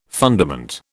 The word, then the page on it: fundament: British English